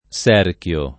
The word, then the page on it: Serchio: [ S$ rk L o ]; Tosc.